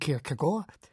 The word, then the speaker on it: Kierkegaard: Søren Kierkegaard, dänisch ausgesprochen Kierkegaard, ist heute vor 200 Jahren geboren als jüngstes von 7 Kindern des Wollhändlers Michael Kierkegaard.